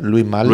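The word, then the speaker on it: Louis Malle: ¿Louis Mal se pronuncia? Louis Mal. – Louis Mal.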